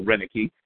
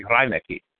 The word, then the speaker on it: Reineke: And I think it's actually Americans who flattened out the pronunciation and started calling people Reineke, as opposed to the proper German pronunciation, which you were correct, would be Reineke, would be the proper German pronunciation.